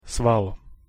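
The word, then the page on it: sval: Slovak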